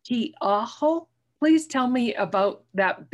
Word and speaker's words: Tiaho: It's spelled T-I-A as an apple, H-O, Tiaho.